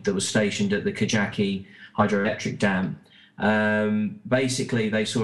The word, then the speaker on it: Kajaki: No, it's a hard J, so it's Kajaki, the true story.